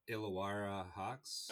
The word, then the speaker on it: Illawarra Hawks: Illawarra Hawks.